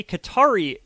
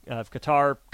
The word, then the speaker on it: Qatar: So a Qatari investment group, the Qatari Investment Authority, kind of similar to the Saudi Arabian group that invested in LIV.